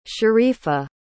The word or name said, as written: sharifah